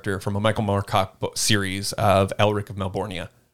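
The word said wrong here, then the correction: Melniboné: Or Melnibone, sorry. I always pronounce it wrong. Melnibone.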